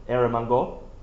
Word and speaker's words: Eromanga: Is that how you say it? Eromanga. There you go. He's got it.